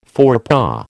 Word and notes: forepaw: American English

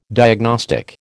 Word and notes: diagnostic: American English

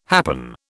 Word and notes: happen: British English

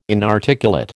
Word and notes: inarticulate: American English

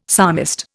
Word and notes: psalmist: Indian

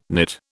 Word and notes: knit: British English

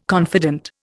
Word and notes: confident: Indian

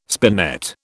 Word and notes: spinet: British English

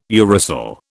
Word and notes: uracil: British English

